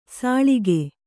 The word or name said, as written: ಸೊಳಗ